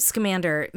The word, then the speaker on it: Scamander: But he did a Skellmander. Am I saying that right? Magical Beast. – Newt Scamander.